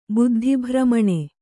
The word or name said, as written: ಬುದ್ಧಿಭ್ರಮಣೆ